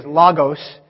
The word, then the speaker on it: Logos: The proper way to say it is Logos. I'm just not used to saying it that way, but Jesus is the Logos of God.